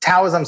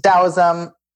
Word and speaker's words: Taoism: Some people pronounce it Taoism. Some people pronounce it Taoism. Tao Te Ching. Both pronunciations I think are okay.